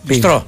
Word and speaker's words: Μπιστρό: Να πούμε την καλημέρα μας στον Παναγιώτη, τον Γίγαντα από το Πιτρό. – Μπιτρό δεν υπάρχει. Μπιτρό. – Ναι. – Μπιστρό λέγεται. – Μπιστρό. Εντάξει, ναι. Μπιστρό. Δεν τονίζετε πολλές φορές το S.